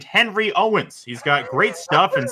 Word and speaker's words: Henry Owens: Henry Owens, tall, lanky lefty.